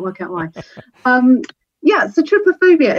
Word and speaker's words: trypophobia: I believe it's pronounced trypophobia, but I'll stand corrected. Tell us about this syndrome. And first of all, is that how you say it? Trypophobia? – That's how I say it.